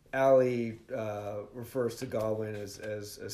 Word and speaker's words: Gawain: You've been pronouncing it Gawain. It's Gawain.